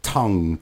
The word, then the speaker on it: tongue: Do we pronounce the G or not in tongue, tongue, tongue? No, we don't.